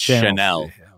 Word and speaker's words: Chanel: I follow this channel called Channel. Jesus Christ. Channel. – It's okay. Actually, Channel's making a comeback. I've heard like media execs talk about it. It's actually pronounced Chanel.